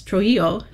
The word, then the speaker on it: Trujillo: We are pulling fragile historic music from cathedral archives across the Americas and the Codex Trujillo de Peru. I hope I pronounced that right. – You're great. The pronunciation is perfect.